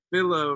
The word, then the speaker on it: Philo: I like to pronounce my name Philo, rhyming with willow, not Philo.